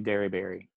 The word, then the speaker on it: Dearybury: So I was blessed with the last name Derryberry. There is not many of us, and especially the way that I spell it, D-E-A-R-Y-B-U-R-Y is how you spell my last name.